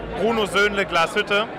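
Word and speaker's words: Bruno Söhnle Glashütte: And I'm here with our brand Bruno Zonla Glashütte. That's the German pronunciation of our brand.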